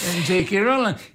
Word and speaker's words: J.K. Rowling: Per esempio è uscito, è stato pubblicato il primo di una serie di romanzi fantasy scritti da J.K. Rowling, ho detto bene? – Sì, J.K. Rowling.